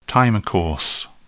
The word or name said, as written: time course